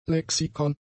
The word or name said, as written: Lexikon